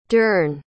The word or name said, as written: dern